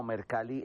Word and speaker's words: Mercalli: Mercalli está bien. Digamos que, por cuestiones, hay muchas similitudes en cuanto al italiano con el español. Y Giuseppe Mercalli era justamente italiano y se puede pronunciar de esa manera.